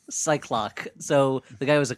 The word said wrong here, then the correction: Psylocke: How do you pronounce that? – Well, Psylocke? – Yeah, Psylocke.